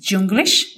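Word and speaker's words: YouGlish: Algunos de los recursos que yo usé, por ejemplo, que son gratuitos, es Junglish.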